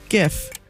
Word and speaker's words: GIF: Oh, and it was just, also, thank you for saying it correctly. GIF.